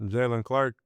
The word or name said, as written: jaylen CLARK